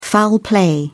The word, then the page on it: foul play: British English